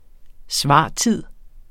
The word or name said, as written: svartid